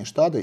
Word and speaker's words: STADA: Иван, для людей, которые немножко знают немецкий язык, написание названия компании «STADA» и произношение компании «STADA» «СТ» читается как «ШТ», «ШТ» указывает на немецкое происхождение компании, на немецкие корни.